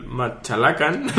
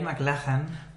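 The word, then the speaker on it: MacLachlan: En, off the record, estábamos todos con el misterio, porque yo sé cómo conoce a Kyle Machalacan, Pero Paco estaban ahí como diciendo, ¿cómo, cómo será? – Porque... – Eh, ¿Cómo pronuncias tú? – Yo, Kyle McLachlan. Yo, McLachlan, ¿vale? – Pero desde luego, Machalacan.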